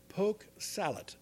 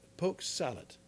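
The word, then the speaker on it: poke salat: But my grandpa would go out to the farm, or the garden in the farm, and pick what we, now, this is mispronounced by most people, poke salad, S-A-L-A-T, poke salad.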